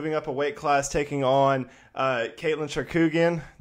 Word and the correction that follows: Chookagian: Did I butcher that last name? Char- Chookagian. So Chookagian.